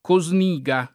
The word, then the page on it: Cosniga: lingua italiana